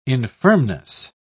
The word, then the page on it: infirmness: British English